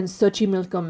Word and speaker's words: Xochimilco: Specifically, I am here to tell you about the Island of the Dolls, or Isla de las Muñecas in Xochimilco, Mexico. That's right. I said it. – I'll just take your word for it. – Say what? – I'll just take your word on that's how it's said.